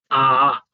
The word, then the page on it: aa: Sanskrit